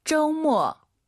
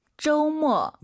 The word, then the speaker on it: 周末: 오유는 어우예요. 그리고 두 번째 글자는 모어 어가 들려야 됩니다. 잘 들어보세요. – 쪼모. – 한 번 더. – 쪼모. – 여러분 따라하셔야죠. 쪼모 아니고 쪼우 쪼모.